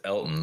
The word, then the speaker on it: Elgton: I think it's just Elton, but there's a G in there for some reason. But yeah, it's like Elton. It's spelled Elgton, but I think it's just Elton Jenkins.